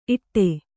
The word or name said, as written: ಇಡೆ